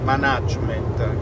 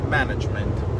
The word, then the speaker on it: management: in quella banca nel management si dice con l'accento sulla prima A eh, eh, non, non dite management, perché non viene da mannaggia, viene da manage, quindi management. Così